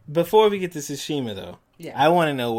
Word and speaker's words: Tsushima: No, but I am a little curious about Tsushima.